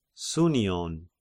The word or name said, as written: Sounion